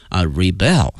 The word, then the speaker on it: rebel: 这里面有一个词呢叫做叛军 rebel rebel，它的拼写呢是 R-E-B-E-L rebel。那么这个词呢它还有的动词的一个意思，表示叛乱 rebel，它的发音呢发生了变化，重音往后挪了一下，名词叛军叫 rebel。